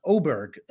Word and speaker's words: Åberg: But this guy, it's pronounced Åberg, that little, those two dots Åberg on top of the A. Right. Yeah. Apparently in Swedish mean you pronounce it Åberg, Ludvig Åberg.